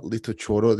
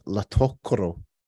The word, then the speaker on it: Litochoro: It's not Litochoro. They pronounce it as Latokoro.